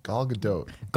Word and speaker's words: Gal Gadot: Gal Gadot.